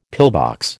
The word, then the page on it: pillbox: American English